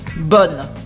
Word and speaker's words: bonne: A única diferença é do masculino para o feminino que a gente muda a pronúncia. Bon, bonne.